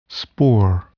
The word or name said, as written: spore